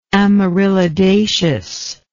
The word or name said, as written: amaryllidaceous